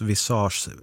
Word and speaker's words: visage: PS, visage uttalas visage, ansikte på franska.